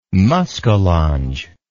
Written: muskallonge

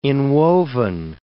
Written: inwoven

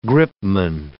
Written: gripman

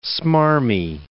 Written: smarmy

